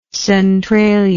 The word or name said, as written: Centralia